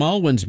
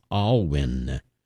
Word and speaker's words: Alwyn: By the way, the composer's name is pronounced Alwyn, William Alwyn.